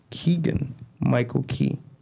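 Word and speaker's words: Keegan-Michael Key: Wait, that's how you say his name? Keegan Michael Keegan? Yeah, Keegan. Look, it's right here. Keegan Michael Key. Oh, okay. So you see, you you you fucked me up. No, that's how they do it. That's how they spell it. So his last name is Key.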